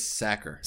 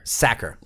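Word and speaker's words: Sachar: Louis Sacker. – Sacker.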